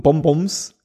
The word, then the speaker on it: Bonbon: Sag Bong. – Bong. Und dann sag nochmal Bong. Bong, Bong. Und dann bist du fertig. – Okay. Aber ich habe trotzdem das Gefühl, ich muss das so komisch aussprechen. – Okay, Bonbon.